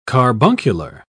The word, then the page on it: carbuncular: British English